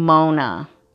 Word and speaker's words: Monon: So when the people pronounce it on the documentary, it sounds like they're saying Mona. – Okay. – But it's spelled M-O-N-O-N, like Monin.